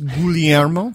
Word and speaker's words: Guglielmo: We were having a debate before on how to say his name. So I think it's Guglielmo. Guglielmo Marconi. – You stick to the Italian pronunciation.